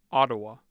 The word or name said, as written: ottawa